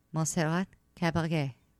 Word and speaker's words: Caballé: Montserrat Caballé.